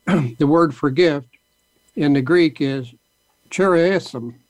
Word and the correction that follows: charisma: I think it's is it charisma? – Oh, is it? Yeah. – C-H-A. – Oh, yeah, charisma. – R-I-S-M-A is charisma.